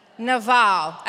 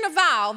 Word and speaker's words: Nabal: Your Bible spells it N-A-B-A-L, but I want you to repeat after me the Hebrew pronunciation, which is Naval. Repeat after me, Naval. Okay, so when we hear Naval, this is what I want you to think.